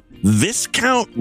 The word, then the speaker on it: Viscount: The What Not to Wear star said the late Queen's sister, whose son, Viscount Linley? Is that how you pronounce that name? It's literally discount, but with a V.